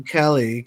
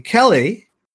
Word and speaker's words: Kelle: K-E-L-L-E. That's all I would say that. I'm not sure how they're pronouncing that. There's Kelly. Kelly. – Kelly, okay, I get that.